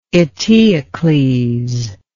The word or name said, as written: Eteocles